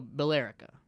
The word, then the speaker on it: Billerica: But it's, like, something about, like, there's a town called, like, Billerica that's spelt, like, B-I-L-L-E-R-I-C-A. So, Billerica. – But it's pronounced Billerica.